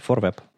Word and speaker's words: ForWeb: Ну, мне кажется, если сравнить э, э, тематику новостную у как бы у, фор, у ForWeb Dev, э, у ForWeb, э, я правильно говорю, да? ForWeb. Да. Да, если сравнить ForWeb и веб-стандарты, то тематика отличается.